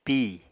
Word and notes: pí: apatani

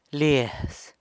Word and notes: liaz: sanlucasquiavini

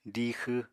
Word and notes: diixw: apatani

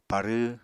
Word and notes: parw: Apatani

